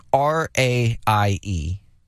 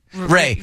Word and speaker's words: Raie: Selena, how would you pronounce this name? R-A-I-E. – Ray. – Not Ra-I-E? Ray. Well, let's go with Ray.